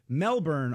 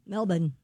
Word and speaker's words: Melbourne: Melbourne, Australia, I guess it's Melbourne. – Melbourne. Mm-hmm. – That's the way you say it, more like Melbourne.